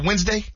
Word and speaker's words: Wednesday: Is it Wednesday? – It is.